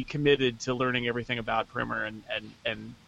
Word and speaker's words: Primer: I'm going to go with the clever way, which is to say I'm going to take Shane Carruth's cult time travel movie Primer. You might call it Primer. That's the wrong way to pronounce it, but that's fine, whatever.